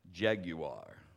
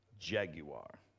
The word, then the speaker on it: Jaguar: Years ago, my beautiful wife, she wanted, and if you watch the commercials, this is how they pronounce it, Jaguar. She wanted a Jaguar. Well, I say Jaguar, but Jaguar.